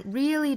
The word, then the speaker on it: really: 那我们看 really 这个词，它第一个这个 r 是在元音前面，所以它的发音应该是 r r r。但是我们看这个单词后面就没有字母二了，所以说一定记得不要再卷舌了。